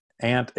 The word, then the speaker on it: aunt: And in this Geico commercial, I had to look it up on the internet to get it exactly, but it's called Ant Infestation. I'm pronouncing A-U-N-T as ant, so you could say aunt, and I'm not talking about little bugs, but this is ladies.